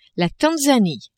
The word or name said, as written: Tanzanie